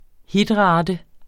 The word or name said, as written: Hitrate